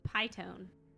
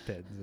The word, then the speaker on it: piton: How do you say it? – It's Piton. – Is it Piton? – Yeah, the beer is called Piton.